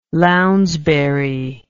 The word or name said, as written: Lounsbury